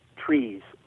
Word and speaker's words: TRIZ: TRIZ is pronounced trees, like TREEZ.